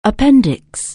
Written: appendicitis

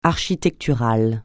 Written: architectural

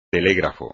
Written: telégrafo

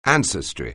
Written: ancestry

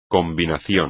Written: combinación

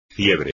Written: fiebre

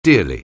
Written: dearly

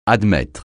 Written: admettre

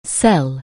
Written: sell

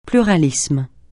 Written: pluralisme

